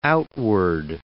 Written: outward

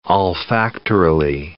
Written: olfactorily

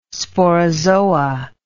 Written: sporozoa

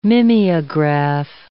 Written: mimeograph

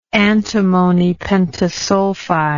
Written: antimony pentasulfide

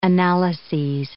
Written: analyses

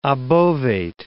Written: obovate

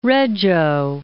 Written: Reggio

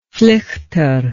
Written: flichter

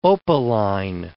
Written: opaline

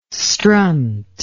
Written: strunt

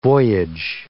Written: voyage

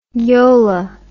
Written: yola

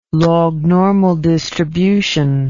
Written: lognormal distribution

